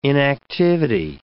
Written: inactivity

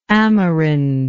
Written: amerindic

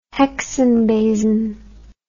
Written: hexenbesen

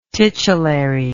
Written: titulary

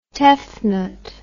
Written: Tefnut

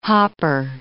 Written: hopper

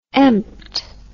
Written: empt

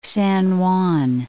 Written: San Juan

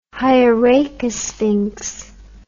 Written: hieracosphinx

